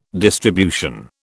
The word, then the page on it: distribution: British English